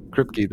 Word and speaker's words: Kripke: The show creator, Eric, is it Kripke? – Kripke, that's it.